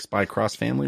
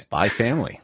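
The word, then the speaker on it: Spy × Family: Does that, it's Spy X Family, like Spy Cross Family? – Or something? Yeah, but I've never heard anyone pronounce the X or okay. Cross, so I stopped doing it.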